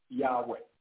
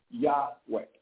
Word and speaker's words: Yahweh: Now we know, and if you do a word study on Jehovah, they will tell you that it was probably pronounced Yahweh, Yahweh, with a Y, if you ever go look it up.